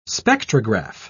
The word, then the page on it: spectrograph: British English